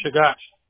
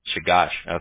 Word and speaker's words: Chugach: How do you pronounce that street name? – Chagash. – Chagash. Okay. I was going to say, it's like a nightmare. – A mountain range in Alaska.